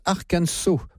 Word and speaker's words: Arkansas: Au départ de cette émission, ça s'écrit Arkansas, mais ça se prononce Arkansas. Donc les affaires sont claires, je dirais Arkansas tout au long de cette émission.